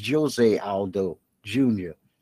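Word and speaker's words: José Aldo: Junior Jose Aldo Jr., and I always say that wrong, it's Jose Aldo Jr., became the first 2023 inductee into the UFC Hall of Fame.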